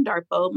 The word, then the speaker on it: Darpoh: So you can follow me on Instagram at Nurse Kim Darpoh. My last name is spelled D-A-R-P-O-H.